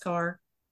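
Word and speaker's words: Kerr: Just to kind of help us put into context where, and it's interesting, you say John Carr, but it's K-E-R-R, right? But it's pronounced Carr. – It is pronounced car, like C-A-R.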